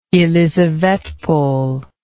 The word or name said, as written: elisavetpol